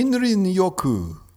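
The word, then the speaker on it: Shinrin-yoku: Let me pronounce that for you. – Yeah. Coined by the Japanese Ministry of Agriculture... – Shinrin Yoku.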